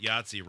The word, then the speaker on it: Yahtzee: It's weird that Yahtzee rhymes with Nazi.